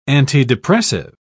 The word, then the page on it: antidepressive: British English